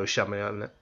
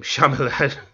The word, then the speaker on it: Shyamalan: Now, for those of you that don't know Shyamalan, God, I'm butchering his name, Shyamalan.